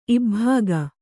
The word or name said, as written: ಇಬ್ಭಾಗ